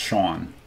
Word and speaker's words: Hsing: I was told his name is pronounced Sean. It's spelled Hsing. So I, up until that point, I was calling him Hsang, but I guess he actually is. People call him Sean.